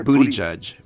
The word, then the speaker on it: Buttigieg: But I love his comments, and Buttigieg, Buttigieg, I can't even say his name. Booty. – What did we just say? Booty, booty, judge. Booty judge.